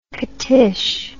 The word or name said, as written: catish